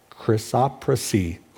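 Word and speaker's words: chrysoprase: Chrysopra, chrysoprasy. That's how you pronounce it.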